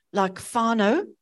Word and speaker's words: whānau: So a word like whānau, which is family, is spelled W-H-A-N-A-U, whānau. And many people who are new to Māori would read whānau, but they will soon remind you that WH, which is then .156, is pronounced F.